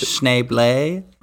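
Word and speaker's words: Schneebly: It's actually pronounced Schneeblay.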